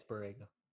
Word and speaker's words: Borrego: They had just got a couple big wins over Toronto and Miami, and Borrego, am I pronouncing that right? Borrego? – Yeah, it's Borrego.